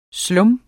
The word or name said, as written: slum